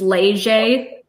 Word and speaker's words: Leger: Yeah, the name, the last name is Cajun French. It's Leger. It looks, yeah, it's hard to pronounce, but yeah, Bethany Leger, exactly.